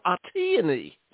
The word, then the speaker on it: Atiyani: Yeah, my name, you're doing really well, and even better is Atiyani, like a peony, Atiyani. – Wow. I had my syllables all wrong. Atiyani.